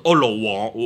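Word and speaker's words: 盧綰: 怎麼讀我這個不懂看看先這個例如啊其中劉邦一個他的一個我查查個字怎麼讀先等陣啊等陣先哎呀等陣啊看埋個粵音怎麼讀先例如就盧王我讀個王字盧王啊，其中一個劉邦嘅一個部下啦。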